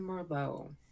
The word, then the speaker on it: Merlot: I am Merlot. – I am... Spell that last one for me. – M-E-R-L-O-T. The T is silent.